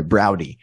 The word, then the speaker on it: Braude: The name is pronounced Browdy as in howdy. – Oh, sorry, Browdy.